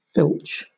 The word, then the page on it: filch: British English